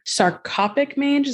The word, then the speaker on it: sarcoptic mange: So you speaking specifically for sarcoptic mange, is that how you say it? – Sarcoptic mange, yeah.